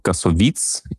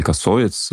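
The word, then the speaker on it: Кассовиц: Я знаю, что вот тот, кто играл Нино, Матьё Касовиц или Касовиц, да, вот тут, опять же, ударение может иметь разные варианты, он тоже был связан с Бессоном и тоже, по-моему, как-то был задействован в съемках этой картины.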